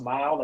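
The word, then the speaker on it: Myle: We talk about Mile and the way it's pronounced Mile, like when you drive, but it's with a Y instead of an I because it's all about you.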